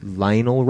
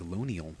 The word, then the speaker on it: Lonial: Lionel Robinson, Let Live. – It's not Lionel, it's like Lonial.